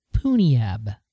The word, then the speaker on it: Punjab: I'm being told in the chat room that it's pronounced Poonyab. – Oh, I thought it was Punjab.